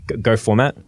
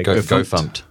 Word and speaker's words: gofmt: But I think one of the interesting things about Go particularly is kind of like Go format, which I think is the correct way to say it. I know people say Go Fumped. – Go Fumped. – It's about G-O-F-M-T.